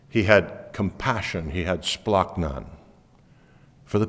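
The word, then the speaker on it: splachnon (σπλάγχνον): The word compassion in the original language is splachnon. And if you say it as it should be pronounced, it's splachnon. That sounds guttural, doesn't it? It's because it's supposed to. It splachnon, or the compassion that Mark is defining or describing about Jesus, comes from the original meaning, from the bowels.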